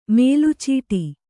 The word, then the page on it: ಮೇಲುಚೀಟಿ: mēlu cīṭi